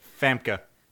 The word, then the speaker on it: Famke: Famke.